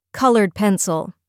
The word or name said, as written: coloured pencil